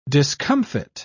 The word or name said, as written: discomfit